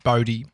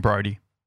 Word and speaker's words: Bodhi: My son Dan is Bodhi, B-O-D-H-I, like Bodhi. I'd rather them say Bodhi. Mm. They're not looking at it though, because that's how it's spelt. And everyone calls him Brody. – Yeah, it's just the go-to. I mean, they're registering Bodhi in a split second and just adding the R in. Brody.